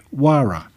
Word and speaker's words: Huayra: The Quechua language means the name is pronounced Huayra with a silent H.